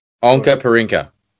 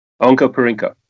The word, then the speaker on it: Onkaparinga: Are we saying it right? – Yeah, Onkaparinka. – Onkaparinka.